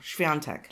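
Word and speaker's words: Świątek: It's the definitely second syllable. Swiatek.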